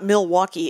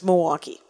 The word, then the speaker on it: Milwaukee: And Milwaukee is not Milwaukee. It's Milwaukee. No L.